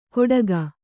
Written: ಕೊಡಗ

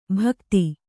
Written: ಭಕ್ತಿ

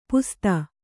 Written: ಪಸದ

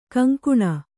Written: ಘಿಂಘಿಣಿ

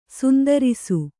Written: ಶಾಂತರಸ

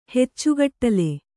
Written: ಹೆಚ್ಚುಗಟ್ಲೆ

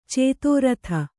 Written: ಚತುರ್ಥಿ

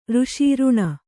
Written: ಋಷಿ

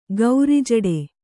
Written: ಗೌರಿಜಡೆ

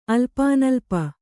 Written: ಅಲ್ಪಾನಲ್ಪ

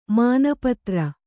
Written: ಮಾನಪತ್ರ

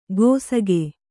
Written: ಗೋಸಗೆ

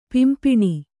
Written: ಪಿಂಪಿಣಿ

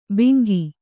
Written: ಬಿಂಗಿ

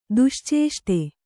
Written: ದುಶ್ಚೇಷ್ಟೆ

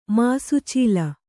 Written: ಮಾಸುಚೀಲ

